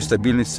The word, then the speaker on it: стабильность: И наверху такую стабильность ценили – Стабильность. Именно с таким ударением на первом слоге любовно выговаривал это слово Брежневский зав.